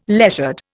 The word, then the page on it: leisured: Indian